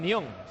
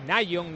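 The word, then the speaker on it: Nyom: Sí, parece que está bien Nión. Se pronuncia así, ¿no? Nión, no es Nayon.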